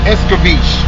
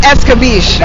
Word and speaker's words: escabeche: What's I see on the menu they got jerk chicken, curry chicken, jerk pork, and I'm not quite sure how to pronounce that last one. What about you? – You know I'll tear it up. Bitch, fish? How do you pronounce it? – We apologize. – So now if you want to ask exactly what it is, that's escabeche. – Oh, escabeche. – Escabeche.